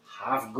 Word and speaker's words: half: Half, good pronunciation. Eliminate the L.